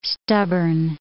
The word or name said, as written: stubborn